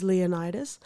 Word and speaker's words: Leonidas: Moving forward, you will hear us refer to Leonidas as Leonidas. There are some historical accounts I remember watching in high school that called him Leonidas.